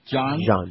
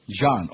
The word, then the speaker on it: Jean: Am I saying that right? Wyclef Jean? – Jean. – Oh, it is the frog thing. Well, that's because it's a Haitian thing.